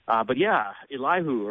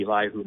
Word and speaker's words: Elihu: Elihu or Elihu, pronounce it either way. I'm going to say Elihu myself, but his name means he is my God.